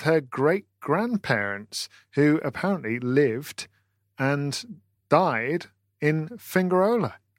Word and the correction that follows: Fuengirola: Matt, have I said that correctly? – No, I don't think so, but... – How'd you say it? – Fuengirola.